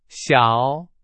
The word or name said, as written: 小